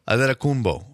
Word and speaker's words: Antetokounmpo: Okay, so it's Giannis Antetokounmpo. – Mm-hmm. I told you, with a B, not a P. – A-H-D-E-H-T-O-H. So that's Adeto. Mm-hmm. Antetokounmpo. – I knew. – Loop him in there. But B-O-H at the end.